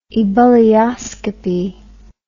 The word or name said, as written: ebullioscopically